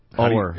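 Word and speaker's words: Oher: NFL lineman Michael, is it Orr? – Orr.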